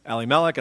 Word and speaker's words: Elimelech: And Elimelech, which I talked to my rabbi friend this week, and we have always pronounced it Elimelech, and I pronounced it that way, and she said Elimelech, and I was like, oh.